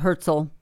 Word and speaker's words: Herzl: Herzl.